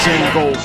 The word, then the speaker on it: Django: But the deer is silent, Django.